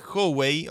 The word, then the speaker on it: Howey: Howey test Recordemos a nuestra querida audiencia, el, test de Howey, o Howey, este, como se pronuncia, H-O-W-E-Y.